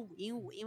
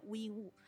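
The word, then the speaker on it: 禹英禹: 那第一部呢，就是最近火遍全球，在韩国收视率将近百分之二十的《 Extraordinary Attorney Wu》，就是非常律师语音语。但是呢，我会读成五音五，因为在韩语里面它就是读成乌音乌。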